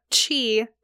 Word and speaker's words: qi: but I want to clarify that it is actually spelt qi. So if I reference any websites or books or anything and I say qi, just know it is spelt qi.